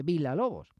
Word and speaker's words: Villa-Lobos: Se escribe Villa-Lobos, pero en portugués, en brasileiro, se dice Villa-Lobos.